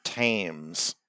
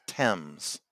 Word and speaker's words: Thames: You said the River Thames. It's Thames, Martin.